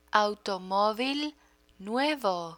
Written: automovil nuevo

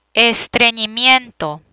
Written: estrenimiento

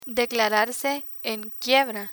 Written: declararse en quiebra

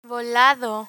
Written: volado

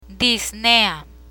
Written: disnea

